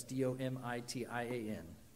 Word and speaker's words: Domitian: Domitian.